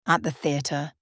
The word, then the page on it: at the teatre: British English